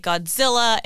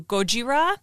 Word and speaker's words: Gojira: And I also want to talk a little bit about the name, because in the anglicized version, we say Godzilla, and you have heard a lot of the Japanese pronunciation is closer to Gojira.